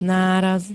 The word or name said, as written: náraz